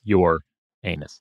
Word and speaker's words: Uranus: It's your anus. Most people, including myself, say Uranus, spelled out as in P and us, to make it more palatable in the English language. The true proper pronunciation is your anus, according to NASA scientists, but most folks just say Uranus.